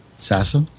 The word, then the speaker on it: Sasso: Sasso. – Oh, okay. Sasso. – S-A-S-S-O.